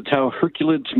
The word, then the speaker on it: Tau Herculid: Okay, did I say it right, Tau Herculid? – Yeah, you got it right. It's the Tau Herculid meteor shower.